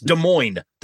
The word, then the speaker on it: Des Moines: and Paul both say the silent S in Des Moines, because it's Des Moines. They're like, all right, Des Moines. No. It's Des Moines. It's not plural. The S is silent.